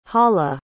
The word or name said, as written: hala